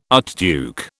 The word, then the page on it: archduke: British English